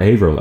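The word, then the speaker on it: Averill: Alan Avril from Primordial, or Avril as he pronounces his own name, has a podcast now.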